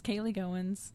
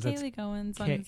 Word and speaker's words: Kaylee Goins: It's just Kaylee Goins. – Kaylee Goins. – Kaylee Goins. K-A-Y-L-E-E-G-O-I-N-S. Phonetically spelled. Although Goins looks like it would be Goins, technically. – Yeah. – So that's not phonetic.